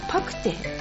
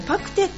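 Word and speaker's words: 肉骨茶: 間違いをしてしまったんですけども実はですね、えー、よいこママさんから来たメールで肉に骨って書いてお茶って書くの何て読むんでしょうスペアリブ違うパクテあそうねパクテって読むらしい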